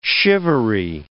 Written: shivery

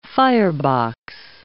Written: firebox

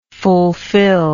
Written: fulfillable

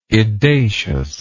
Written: edaciousness

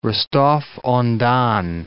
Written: Rostov-on-Don

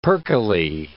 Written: perkily

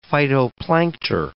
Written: phytoplankter

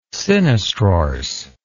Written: sinistrorse

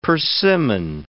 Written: persimmon

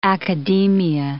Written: academia

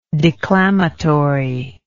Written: declamatorily

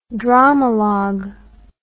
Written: dramalogue

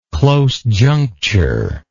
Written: close juncture

